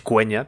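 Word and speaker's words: Quenya: Lo único que nos hace una pequeña puntualización nos la hace Marcos85, que dice que la pronunciación de cueña es cueña, tanto en inglés como en élfico, y que la QU se pronuncia siempre Q, no como en español, por ejemplo.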